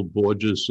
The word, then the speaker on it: Borges: You can go there, go to Phil Borges' website just by typing in his name, B-O-R-G-E-S.